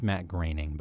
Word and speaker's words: Groening: And it's Matt Groening, by the way.